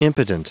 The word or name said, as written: impotent